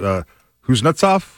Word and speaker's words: Kuznetsov: Kuznetsov.